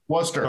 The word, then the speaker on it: Worcester: Is that how you say it? Is it Worcestershire? Worcester, Massachusetts. – Oh, Worcester. – Help me out, America. – Worcester. – Okay. Worcester. – Worcester. Thank you.